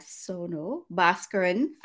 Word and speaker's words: Sonal Bhaskaran: So some of you know who she is, some of you don't, but I am excited to have Sonal Bhaskaran.